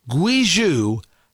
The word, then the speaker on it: Guizhou: But there is an area called Guizhou. G-U-I-Z-H-O-U. I believe it's pronounced Guizhou. Guizhou